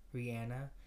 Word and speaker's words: Rihanna: That's how you say her name. Rihanna. – No. – Yeah. – Rihanna? – Rihanna. – No. – Look it up. – How? – That's what she says. She says... – I want to look up her pronouncing her own name. – Yeah, she goes, it's Rihanna.